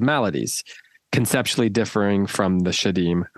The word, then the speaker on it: Shedim: D-A-E-M-O-N? – It's Shadim. Oh, no. Shadim. S-H-E-D-I-M.